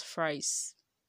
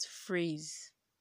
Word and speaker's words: phrase: And phrase is not pronounced fries, but phrase. Phrase.